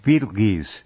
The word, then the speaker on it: Betelgeuse: Betelgeuse. That's the actual pronunciation of that Interesting.